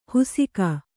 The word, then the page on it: ಹುಸಿಕ: husika